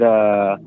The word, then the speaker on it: aswang: We pronounce it aswang. – Aswang? – That's how we pronounce aswang.